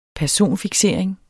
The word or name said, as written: personfiksering